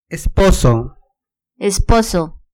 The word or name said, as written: esposo